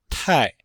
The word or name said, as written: tai4